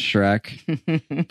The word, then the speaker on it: Schreck: You can find me, I'm on there as Alexander Schreck. Yeah, my last name's Schreck. The movie changed my life. It is spelled a little differently. It's S-C-H-R-E-C-K. Quick little side note.